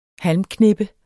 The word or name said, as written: halmknippe